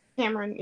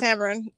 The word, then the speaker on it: Tamron: Is it Tamron Hall? Is that how you say her name? – Tamron, yeah. – It's Tamron, whatever.